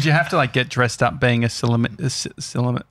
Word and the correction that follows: sommelier: How do you say it? – This is why you don't make fun of other people who can't say it, mate. – Yeah, that's cool. – Silomare? – Sommelier.